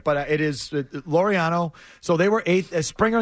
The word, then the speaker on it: Laureano: Oh, isn't it like Laureano? – Laureano, yeah. You got it.